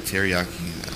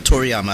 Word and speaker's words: Akira Toriyama: Dang, I messed up. I said teriyaki. – Hold on. I, I, got you. Hold on. Wait, wait, wait. Akira Toriyama. Yeah. Toriyama. I think I pronounced it correctly.